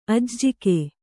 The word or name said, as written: ಅಜ್ಜುಗ